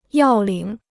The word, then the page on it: 要领: yào lǐng